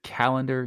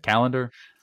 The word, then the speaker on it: Callander: I guess it's the is it the Callander Youth Project Trust? I, don't know if I'm saying that right. Is it Callander? – That's right. Yeah, um, Callander is a small town here in central Scotland, and Callander Youth Project Trust is a social enterprise.